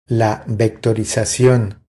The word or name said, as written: La vectorizacion